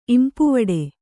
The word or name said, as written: ಇಂಪುವಡೆ